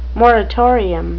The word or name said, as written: moratorium